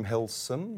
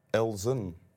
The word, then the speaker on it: Helsen: ik heet Wim Helsen met een S. En veel mensen zeggen Elsen, de Nelzen ze.